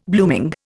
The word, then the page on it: blooming: Indian